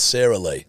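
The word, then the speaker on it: Sara Lee: Sarah Lee, isn't it? – Oh, Sarah. Coozy. No, c'est la vie. – No, not for this one. It's Sarah Lee. – Well, it's spelled S-A-R-A, so I'm sure it's Sarah.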